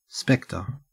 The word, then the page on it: specter: Australian English